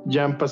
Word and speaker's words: Yampha: Jampa se escribe Y-A-M-P-H-A.